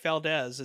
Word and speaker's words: Valdes: So yeah, just follow me on Instagram at Mike Valdes. It's, MikeValdes. It sounds like a Z spelled with an S.